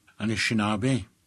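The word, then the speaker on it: Anishinaabe: How do you say the people in Ojibwe? – Anishinabe. Anishinabe.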